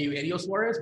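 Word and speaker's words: Eugenio Suárez: I would actually say his name correctly, and I would call him Eugenio Suarez, but that's just me.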